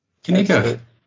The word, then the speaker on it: QNIQR: I'm not sure I pronounced it correctly. It's QNIQR. – Knikker. – Knikker. – Is that what it is? Knikker.